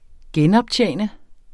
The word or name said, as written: genoptjene